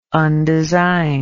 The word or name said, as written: undesigned